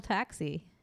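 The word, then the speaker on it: taksi: How'd they spell taxi? – Oh, T-A... KSI. – That makes sense. That's how it's said.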